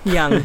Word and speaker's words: Jung: Is it Jung? It's Jung, right? – Jung. – there we go.